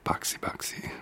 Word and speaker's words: Baixi: And on we go with Boxy, B-A-I-X-I, Boxy. That's how I pronounce it anyway,